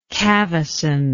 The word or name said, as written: cavesson